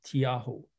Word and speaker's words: Tiaho: It's spelled T-I-A as an apple, H-O, Tiaho.